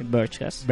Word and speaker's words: Vergecast: De momento, eh, por ejemplo, para poner un ejemplo, si, si buscáis de Vergecast, ¿lo he dicho bien? Sí, Vergecast.